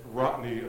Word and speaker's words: Rotney: And it Rotney apparently just happens to rhyme with Rodney.